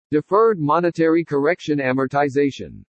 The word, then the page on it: DEFERRED MONETARY CORRECTION AMORTIZATION: english